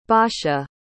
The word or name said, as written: basha